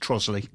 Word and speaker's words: Trotterscliff: I mean, to be fair, that is the worst one because it is actually spelt Trotterscliff, but it's actually pronounced Trosley.